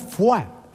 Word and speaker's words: foie: Fua, que es una palabra francesa que a mí siempre me ha llamado mucho la atención porque se escribe foie, ¿eh? Y se pronuncia fua. Fíjate, la O, la I y la E se escribe y luego se pronuncia con la U.